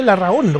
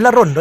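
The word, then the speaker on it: Larrahondo: Sí, como estamos en Chile, Larrajondo, porque la H es mudita, no se pronuncia. Larrajondo, no es Larrondo, es Larrajondo. Con doble R, y alarga un poco la sílaba de la H, entre la H y la O.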